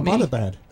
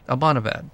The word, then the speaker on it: Abbottabad: Abbottabad. – Abbottabad.